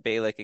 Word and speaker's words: Bejlek: B-E-J-L-E-K. – I think it's Bjelić.